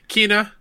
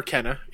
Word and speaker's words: Kena: Kena or Kenna, either way.